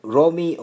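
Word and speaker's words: Römi: Actually, it's like, R-O-M-I, I just say that, like, as an intro, you know, in the songs. The name is Romi, so it's like, R-O with the umlaut on it, and then M-I is a German name.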